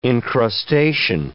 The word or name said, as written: encrustation